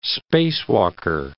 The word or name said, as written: spacewalker